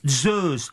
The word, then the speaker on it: Zeus: Et dans le grec Zeus, alors le Z grec qui s'appelle zeta se prononce DS. On dit Zeus.